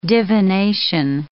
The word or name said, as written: divination